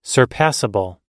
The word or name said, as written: surpassable